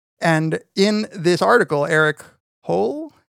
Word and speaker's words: Hoel: H-O-E-L. – It seems like, yeah. Oh, yeah. H-O-E-L. – Yeah. – Yeah. Hole. – Hole.